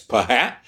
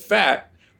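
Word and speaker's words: phat: Okay, well, if P-H is F, and fat is a slang term, and I spell it with P-H, but I pronounce it fat, I'm reinforcing my linguistic loyalty to my culture.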